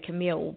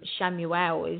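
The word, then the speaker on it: Chamuel: Shamil, it depends how you pronounce it into where you are. I say Camille, but I think in Shamuel is what you say in the States.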